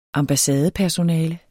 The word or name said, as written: ambassadepersonale